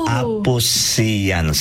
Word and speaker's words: Aposians: So, anyway, to correct it, the pronunciation of Aposians. – Oh! – Aposians.